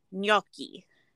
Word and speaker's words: gnocchi: So it's pronounced gnocchi, not gnocchi. – Ugh, is that what he said? – Yes. So think of it how we say lasagna with the GN. It's the nya sound.